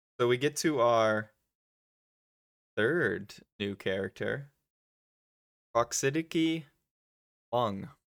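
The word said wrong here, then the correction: Praxidike Meng: Praxidiki is how they pronounce it. – Praxidiki. What? – That's how they say it. I don't know. – Oh my, that's messed up. – Praxidiki. How do you say it? – Praxidiki. – That's how you say it. – It sounds awful. – Praxidiki Mung. That is the official pronunciation.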